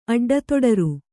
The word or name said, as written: ಅಡ್ಡತೊಡರು